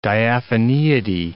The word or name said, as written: diaphaneity